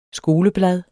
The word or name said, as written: skoleblad